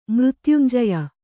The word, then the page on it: ಮೃತ್ಯುಂಜಯ: mřtyunjaya